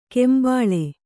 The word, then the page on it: ಕೆಂಬಾಳೆ: kembāḷe